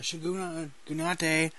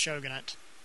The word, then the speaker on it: Shogunate: In an alternative reality where the Tokugawa Shogunate has remained in power. So apparently... – I've always heard that pronounced Shogunate. But Shogunate? – Whatever. – Oh, yeah, you might be right.